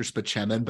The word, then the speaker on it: Spaceman: the joke is that his name is Dr. Spachemin, but it's spelled Dr. Spaceman.